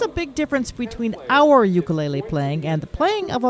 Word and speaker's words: ukulele: It's pronounced ukulele, and it's two Native Hawaiian words, uku, which means flea, and lele, which means jumping. So it's the direct translation is jumping flea, ukulele.